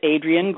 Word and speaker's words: Adrienne: My name is Adrian Gould, and I'll spell that for you. A-D-R-I-E-N-N-E is my first name.